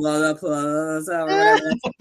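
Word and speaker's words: Lollapalooza: And then everybody kept pronouncing the name wrong. It's called Lola Palooza.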